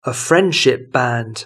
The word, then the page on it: a friendship band: British English